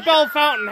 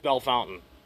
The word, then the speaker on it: Bellefontaine: No, that's the whole thing behind Bell Fountain. It's spelled Bellefontaine. – Like Fontaine? – Yeah. – Yeah, but it's Bell Fountain.